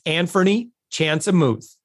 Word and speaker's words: Anfernee Chansamooth: With us right now, it's Anfernee Chansamooth.